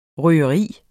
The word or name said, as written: røgeri